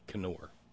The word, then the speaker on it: Knorr: Brother Deacon Asa tells me the TV commercials always pronounce it with the K, Knorr.